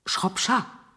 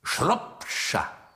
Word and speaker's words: Shropshire: Stimmt meine Aussprache? – Fast. Shropshire, nicht Shire. – Ach ja? – Ja. – Es gefällt mir, wenn du mich verbesserst. Shropshire.